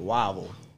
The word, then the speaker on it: Whiteville: We have a place in North Carolina called Whiteville, but we pronounce it Wavel.